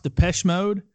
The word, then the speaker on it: Depeche Mode: It's pronounced Depeche Mode. It was an 80s Depeche Mode.